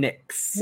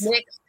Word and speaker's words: Nyx: And we're going to start off with Carmen Petrovic and Ariana Grace versus JC Jane and Jasmine Nye, have I said that right? – Jasmine Nyx. – Nyx. – Yes. – Oh, like the cosmetics.